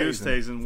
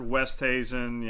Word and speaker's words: Oosthuizen: How do you say that one? – Louis Oosthuizen works. Uh, I've heard Westhuizen. Yeah, okay. – Oosthuizen works. Yeah, Louis.